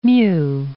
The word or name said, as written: mu